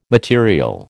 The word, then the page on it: material: American English